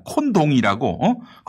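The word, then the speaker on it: Condom: 네, 실제로 있습니다. 콘동이라고. 거기서는 콘동이라고 읽는데 영문자로 읽으면 영락없이 콘돔이에요.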